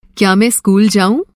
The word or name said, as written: क्या